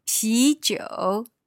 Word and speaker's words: 啤酒: 啤酒. – 啤酒. So that's another kind of 酒. And pee, I guess it sounds a little bit like beer.